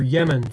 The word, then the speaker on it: Yemen: war on the tiny country of Yemen, or Yemen. Some people pronounce that Yemen.